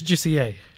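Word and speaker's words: Jussier: How do you pronounce it? – It's Juicier.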